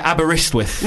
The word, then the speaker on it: Aberystwyth: Oh, no, yeah, you're right. Aberystwyth. Mm-hmm. Yeah, I'm just imagining a T where there isn't one.